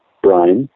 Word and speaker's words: Brien: and I said it exactly how the cult would like to say it, Brian, as in brine shrimp, as in a batch of a brine, but it's spelled in the Gaelic way, B-R-I-E-N, so it could easily have this permutation.